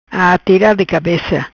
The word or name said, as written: Atira de Cabeca